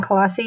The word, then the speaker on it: Colossi: It's Dawn Colossi. Easy to find because there aren't others of me. Last name is spelled Colossi.